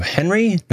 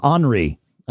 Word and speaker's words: Henri: And here, in Fairfield, Connecticut, having made it through, Henry? No, not Henry. Tropical Storm. This is John F. Braun. – Tropical Storm Henri, I believe. Is the pronunciation they've chosen for their name. – Because it's French. – It is a French name.